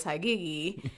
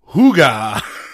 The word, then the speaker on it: hygge: Like, we are so off on how to pronounce it. It's pronounced hygge. – Okay, hygge. – It is spelled H-Y-G-G-E, but apparently in Dutch that's pronounced hygge, which is, it sounds a lot like the English word for hug, it says.